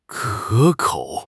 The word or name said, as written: 可口